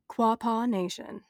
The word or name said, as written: Quapaw